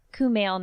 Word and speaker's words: Kumail: and the voice of Camille Nanjiani. – Ooh. – I totally said that wrong, didn't I? – Kumail Nanjiani.